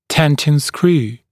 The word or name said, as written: tenting screw